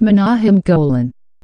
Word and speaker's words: Menahem Golan: Um, So yeah, Cannon was like pretty much made up of two dudes, two Israeli cousins, Menahem Golan Actually, it's pronounced Menahem Golan, and Yoram Globus.